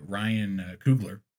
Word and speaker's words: Ryan Coogler: Ryan Coogler, am I saying that right? – Yeah.